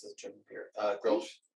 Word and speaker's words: Grolsch: I still think it's Grolsch. I think Grolsch is how you say it. That's how I'd say it.